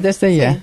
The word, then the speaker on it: Desde Allá: con Desde Allá. L'ho detto giusto? – Hai detto bene, Desde Allá.